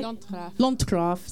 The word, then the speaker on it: Landgraaf: We were in the first cut in Landgraaf, have I said that right? – Landgraaf, sorry.